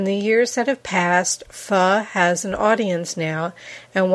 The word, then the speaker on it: pho: A couple years later, ahead of his time, he opened a very simple location on York Road for pho, that's spelled P-H-O, the Vietnamese soup.